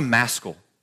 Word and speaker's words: maskil: What's a maskil? Rhymes with rascal, by the way.